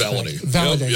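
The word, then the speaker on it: Valladay: Speaking of which, and I can never say his name right, but Xavier Holiday is Valaday. – Sorry about that.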